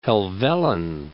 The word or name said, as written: Helvellyn